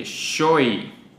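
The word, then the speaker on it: xoy: Siguiente, Shoy, S, bueno, X, O, Y, y se pronuncia S-H, Shoy, perrilla en el ojo, así más o menos como la que tengo yo desde hace rato, una infección o algo así.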